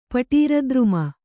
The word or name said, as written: ಪಟೀರದ್ರುಮ